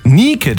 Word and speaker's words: Nike: Ich rede vom US-Sportartikelhersteller Nike oder wie man es eigentlich aussprechen müsste, Nike. Denn das ist in der griechischen Mythologie ja die Göttin des Sieges.